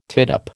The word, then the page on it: tittup: American English